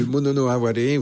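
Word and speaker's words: mono no aware: Le Monono Aware, oui, tout à fait.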